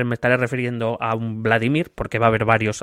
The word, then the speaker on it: Vladimir: Vladimir bueno, por cierto, siempre decimos Vladimir o Vladimir y ninguna de las dos es correcta, es Vladimir, por cierto. Vladimir. Sí, sí, es Vladimir, se tilda en la, sílaba del medio,